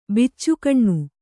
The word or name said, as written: ಬಚ್ಚಗಾನಿ